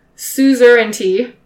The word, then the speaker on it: suzerainty: So that battle effectively ended Hungarian suzerainty.